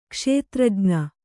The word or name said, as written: ಕ್ಷೇತ್ರಜ್ಞ